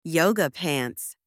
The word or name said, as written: yoga pants